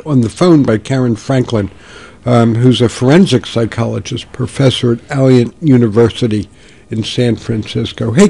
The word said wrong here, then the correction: Alliant: Karen, am I pronouncing that university correctly? – It's actually Alliant. I didn't want to say anything, but it's Alliant.